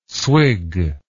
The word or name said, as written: swig